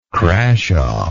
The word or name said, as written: crashaw